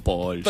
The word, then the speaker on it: Pauls: cómo se pronuncia su apellido? – Bueno, pero ya después de todo este tiempo lo lamento, se pronuncia así. – Igual, como él también. – Gastón Pauls, es Gastón Pauls.